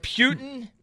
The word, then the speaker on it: Putin: There's no P-Y-U. – I'm refuting for Putin, okay? – Putin, as in P-O-O.